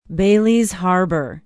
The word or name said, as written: baileys harbor